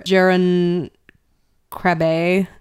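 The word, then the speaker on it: Jeroen Krabbé: Ooh, Jerome Crabbe? Crabbe? How do you pronounce that? – I don't know. So sorry to the Dutch actor, Jeroen Krabbe. – K-R-A-B-B-E.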